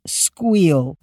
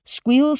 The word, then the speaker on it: Skweel: It's Squeel. They spell it S-K-W-E-E-L, and they hope you'll squeal with delight when you see how much money you can save by smart shopping for your next flight. Squeel